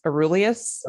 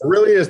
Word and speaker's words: Aurelius: So first I found the book online, Meditations, and I don't even know how to say this cat's name, but Marcus Aurelius. – Aurelius, thank you.